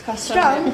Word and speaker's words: Cross Strung: I'm here with Cross Strung. Or Strung, not Strung. I don't know why I said Strung. It's Cross Strung. – Everyone gets it wrong.